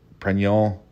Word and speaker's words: Pregnon: Quick aside, it's actually a French pronunciation, like Pregnon.